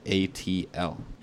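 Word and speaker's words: Couatl: For those of you who don't know how to pronounce this, it's a Kowtl.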